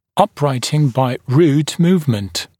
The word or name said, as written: uprighting by root movement